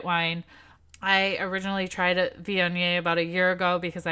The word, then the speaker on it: Viognier: So I am drinking a Viognier, which I did have to Google how to say that because it's spelled V-I-O-G-N-I-E-R, but very explicitly said on the internet, Viognier. So that's how I'm remembering it.